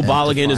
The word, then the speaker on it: Balogun: Flo Balogun is going to be a name that becomes more and more popular. Told now, that's how you say it.